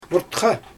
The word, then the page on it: murtxa: Xyzyl